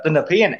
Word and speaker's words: Nepean: Now, I'll help you with the pronunciation. It's the Nepean River there.